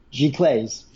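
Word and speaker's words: giclée: and I'm going to butcher the pronunciation, but as Gleesies? – G. Clays.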